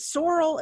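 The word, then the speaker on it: Sorel: And then I ended up ordering, is it sorrel? Is that how you say it? – I think so. That's how I say it.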